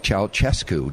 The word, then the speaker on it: Ceaușescu: And they escaped the communist regime of Nicolae Ceaușescu. Did I pronounce that right? – Yes, you did.